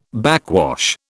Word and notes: backwash: British English

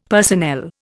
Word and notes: personnel: Indian

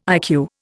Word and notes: IQ: Indian

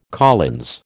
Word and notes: Collins: American English

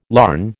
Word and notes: larn: American English

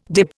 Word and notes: dib: Indian

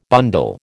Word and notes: bundle: American English

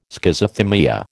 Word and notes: Schizothymia: American English

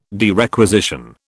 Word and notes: derequisition: British English

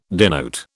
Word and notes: denote: British English

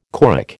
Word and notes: choric: American English

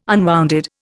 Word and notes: unwounded: Indian

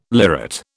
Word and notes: lyrate: British English

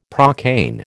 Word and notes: procaine: American English

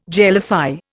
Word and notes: jellify: Indian

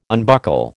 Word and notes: unbuckle: American English

